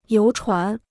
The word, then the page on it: 游船: yóu chuán